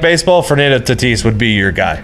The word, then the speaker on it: Fernando Tatis: And the one player that I do know other than Shohei Ohtani is Fernando Tatis.